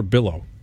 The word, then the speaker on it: billow: Like, as a just a reporter, when smoke starts to bellow a little bit, are you, am I saying that right? Bellow or billow? – Billow.